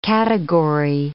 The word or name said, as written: category